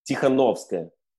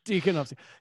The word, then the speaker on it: Tikhanovskaya: Tikhanovskaya. – Yeah. – Tikhanovskaya.